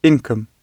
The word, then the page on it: income: British English